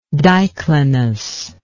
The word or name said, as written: diclinism